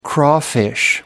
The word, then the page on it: crawfish: British English